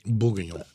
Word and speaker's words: Bourguignon: Bourguignon.